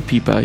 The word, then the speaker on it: Pipa: And I'm going with Pipa, even though there's some discussion about how to pronounce it. – Yeah, Pipa sounds right. – Pipa, P-I-P-A.